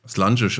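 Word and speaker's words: Slàinte: Slange spricht man das, Slange Mar oder Slange Bar, das schreibt man Slainte.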